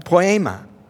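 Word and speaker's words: poema (ποίημα): This idea of workmanship is from a great Greek word called that's pronounced poema.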